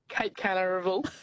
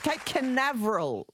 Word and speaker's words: Cape Canaveral: Cape Canaveral. – I think you've Googled it, but you haven't exactly pronounced it properly. But yes, Cape Canaveral, it's called.